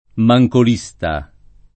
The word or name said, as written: mancolista